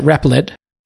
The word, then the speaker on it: Replit: You would pronounce it, Replit. – Yes. – But it is REPL.IT.